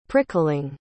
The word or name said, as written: prickling